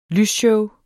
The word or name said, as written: lysshow